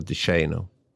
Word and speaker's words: DeShano: Jag har faktiskt hört honom uttala sitt eget namn. Och DeShano vill många säga, men han säger själv att man ska säga DeShano.